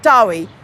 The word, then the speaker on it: Douai: So the seminaries have to be abroad, a Douai, we pronounce it Dowie, in France.